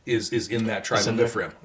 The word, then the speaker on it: Ephraim: so Samuel's father, and they're living in Ephraim. Mm-hmm. Did I pronounce that right? – Yep. – Ephraim? – So which is north.